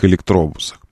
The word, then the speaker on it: электробус: Так что вот что мне удалось выяснить моментально, благодаря нейросетям, о наших московских электробусах. Так, сейчас я открою вот это. Московский электробус. Ударение именно такое. Электробус.